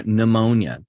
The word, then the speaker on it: pneumonia: Okay, pneumatology or pneumonia. The P is silent.